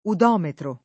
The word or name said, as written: udometro